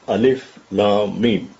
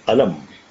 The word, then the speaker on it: Alif Lam Mim: Otherwise, if you find Alif Lam Mim written, you can read it as Alam. Pain. But Quran, in fact, because we do not pronounce them collectively, we pronounce them separately, disjointedly.